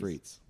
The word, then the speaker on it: frites: Frites. That's, I just told you how to say it. It's frites. It's frites.